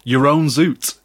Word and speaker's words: Jeroen Zoet: Jeroen Zoet. – Your own zoot is how you say that.